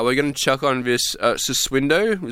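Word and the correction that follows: Sussudio: Is that how you say it? – Susidio by Phil Collins.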